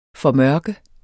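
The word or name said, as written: FORMØRKES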